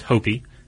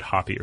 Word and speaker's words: Hopi: I believe the name is pronounced Hopi. And I guess maybe I said Hoppy or something.